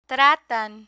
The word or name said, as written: tratan